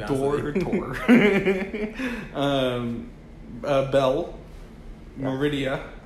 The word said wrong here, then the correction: Merida: It's Merida. I mispronounced. What did I say? Meridia? – Meridia. – Yeah, that's hilarious. – And Merida? – Merida from Brave.